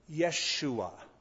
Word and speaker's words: Yeshua: The J is silent, sort of like Spanish. The Hebrew pronunciation of Joshua would be Yeshua.